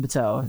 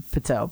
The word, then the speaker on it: Patel: My Instagram is I am, and my name, Deven Patel. It's spelled D-E-V-E-N Patel, P-A-T-E-L. I'll say it again. I am D-E-V-E-N P-A-T-E-L.